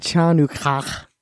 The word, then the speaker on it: Hanukkah: It's like a little kid at Hanukkah or something. I don't know. – Hanukkah. Hanukkah. – I always pronounce it Chanukah.